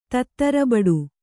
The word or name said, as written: ತತ್ತರಬಡು